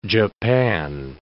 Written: Japan

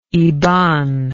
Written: iban

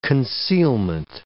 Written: concealment

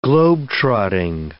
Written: globe-trotting

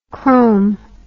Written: chrom

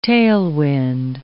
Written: tailwind

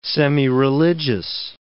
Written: semireligious

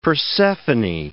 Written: Persephone

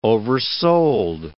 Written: oversold

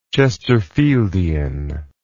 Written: Chesterfieldian

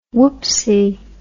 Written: woopsie

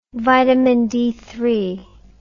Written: vitamin D

